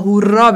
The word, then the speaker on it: هورا: بعد خب هیبی بهورا بعد ماها چون که تلفظ زبانمون هنوز درست نیست اینا دو تا آر باید تلفظ کنی تا هورا بشه. حالت تشدید اگه یه دونه آر داشته باشه هورا معنی فاحشه میده.